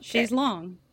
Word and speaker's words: chaise longue: So is it lounge? But that's how you say it in French? – How you say it in French. Okay. Chaise longue.